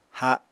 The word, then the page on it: ha7: Apatani